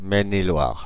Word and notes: Maine-et-Loire: [mɛn.e.lwaʁ]; France (Île-de-France)

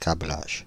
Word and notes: câblage: [kɑ.blaʒ]; Paris

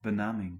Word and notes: benaming: [bə.na.miŋ]

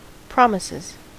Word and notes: promises: American English

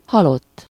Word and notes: halott: /ˈhɒlotː/

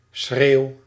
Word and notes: schreeuw: /sxreːu̯/

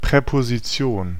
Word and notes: Präposition: /pʀɛpoziˈt͡si̯oːn/

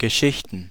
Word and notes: Geschichten: /ɡə.ˈʃɪç.tn̩/

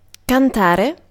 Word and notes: sing: /ˈsɪŋ/; [sɪŋ]; /siːŋ/

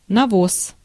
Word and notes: навоз: /nɐˈvos/

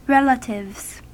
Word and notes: relatives: ˈɹɛl.ə.tɪvz

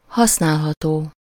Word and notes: bruikbaar: /ˈbrœy̯k.bar/; /ˈbrœk.bar/

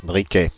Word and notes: briquet: /bʁi.kɛ/; [bʁi.kɛ]

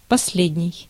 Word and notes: worst: /ˈwɜːst/; /ˈwɝst/

